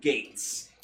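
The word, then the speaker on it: Geiz: but no, so yeah, even though it's spelt like that, it's still pronounced Gates.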